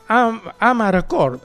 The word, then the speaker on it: amarcord: Io mi ricordo en algún lugar de Italia, sobre todo en Génova, se pronuncia am record. Entonces, amarcord.